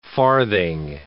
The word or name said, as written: farthing